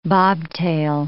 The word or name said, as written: bobtail